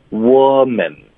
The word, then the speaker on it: woman: 所以针对单数的，它的发音是 wo，是一个 a 的音，woman。